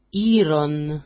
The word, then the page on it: iron: i-ron